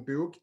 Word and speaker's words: Book: So I'm pretty active on Twitter, and my handle is Joachim Book, the way my name is spelled, Joachim Book, like a book you would read.